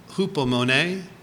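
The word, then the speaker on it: hupomone (ὑπομονή): That's the Greek word hupomone. That's spelled H-U-P-O-M-O-M-E, hupomone.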